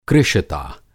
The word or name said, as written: कृशता